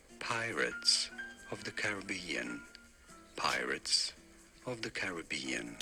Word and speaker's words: Caribbean: It seemed like he said Caribbean.